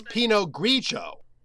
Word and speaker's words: Pinot Grigio: It's Pinot Grigio, because we just said the rule, the I is silent if it's not stressed before another vowel and after the G.